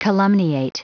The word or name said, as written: calumniate